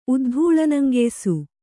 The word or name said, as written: ಉದ್ಧೂಳನಂಗೆಯ್ಸು